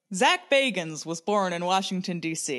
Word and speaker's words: Bagans: He pronounces it Bagans.